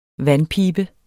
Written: vandpibe

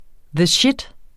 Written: shit

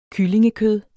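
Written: kyllingekød

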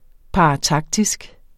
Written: parataktisk

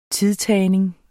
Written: tidtagning

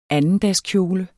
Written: andendagskjole

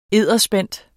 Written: edderspændt

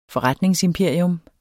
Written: FORRETNINGSIMPERIUM

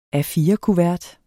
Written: A4-kuvert

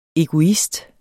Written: EGOISTEN

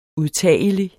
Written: udtagelig

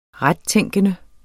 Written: rettænkende

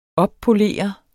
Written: oppolere

